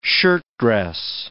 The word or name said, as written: shirtdress